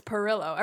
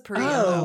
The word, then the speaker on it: Perillo: So he's Italian and they just spell it weird. It's Perillo. Everyone says Perillo.